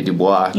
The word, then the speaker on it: Dubois: We'll put the, I can't say Dubois.